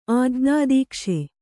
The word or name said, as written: ಆಜ್ಞಾದೀಕ್ಷೆ